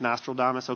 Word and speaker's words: Nostradamus: It's like Nostradamus, okay.